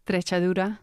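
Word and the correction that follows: Treixadura: No, Trexadura.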